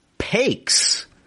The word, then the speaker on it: Pakes: Number 2, Reed Pakes. It's spelled P-A-K-E-S. It's like a lazy way to say pancakes.